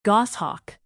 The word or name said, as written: goshawk